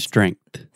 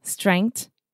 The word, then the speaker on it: strength: Strength. – Strength. – There's a TH at the end. Strength.